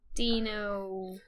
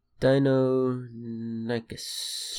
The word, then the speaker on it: Deinonychus: So, which lived about 110 million years ago, which was the Deinocheus. – Deinonychus.